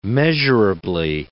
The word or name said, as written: measurably